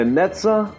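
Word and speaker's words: Netzach: Errei muito a pronúncia? – Muito bom, muito bom. É Netzah e Resed, só. O resto tá certo.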